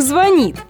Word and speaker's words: звонит: Вам кто-то звонит или все же звонит? Сомнения прочь. Вариант один, единственный, верный. Звонит. Итак, еще раз. Звонит.